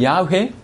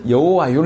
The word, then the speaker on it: 야훼: 그거를 이제 이렇게 자음을 약간 넣어서 발음한 이 비슷한 발음이 야훼 또는 여호와 이런 뜻입니다.